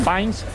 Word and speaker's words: Fiennes: Exactamente, Ralph Fiennes ¿En? Fines, que Fines.